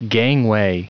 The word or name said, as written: gangway